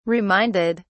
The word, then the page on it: reminded: British English